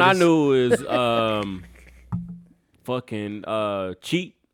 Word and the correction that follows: Chet: or however you pronounce the name, Holmgren. – Oh yeah, Chet.